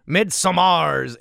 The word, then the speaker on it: Midsommar: I love the Midsummer's ending explained. Oh, I'm sorry, I've said that wrong. Midsommar's ending explained.